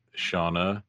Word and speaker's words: Shawna: And to let everyone know, it's spelled S-H-A-W-N-A, Shawna. And Linzy, it's a little bit different. It's L-I-N-Z-Y.